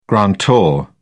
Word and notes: grantor: British English

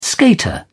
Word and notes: skater: British English

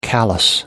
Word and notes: callous: American English

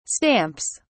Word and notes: stamps: British English

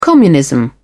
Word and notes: communism: British English

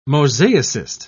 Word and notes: mosaicist: British English

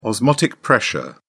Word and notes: osmotic pressure: British English

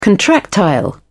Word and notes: contractile: British English

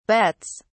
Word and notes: bets: British English

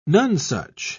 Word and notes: nonsuch: British English

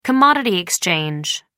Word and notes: commodity exchange: American English

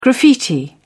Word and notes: graffiti: British English

